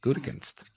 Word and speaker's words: Gutekunst: How do you pronounce the GM of the Packers? – Gunst. There you go. Gutekunst. – General manager of the Packers. Do you know that I had to go on Google pronunciator to see how to pronounce that? Gutekunst.